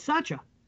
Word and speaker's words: Sacha: It's Sacha. S-A-C-H-A.